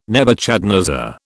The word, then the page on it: Nebuchadnezzar: British English